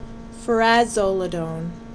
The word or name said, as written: Furazolodone